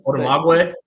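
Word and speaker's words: Odumegwu: They were able to allocated Kenneth, and I want to make sure I get his last name right, Udumego. – Sure. – he gave us a phonetic pronunciation of it. So Odumagwe.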